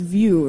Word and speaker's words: Vue: Okay, it's pronounced VU, right? – VU. – VU, but yeah. Spelled V-U-E.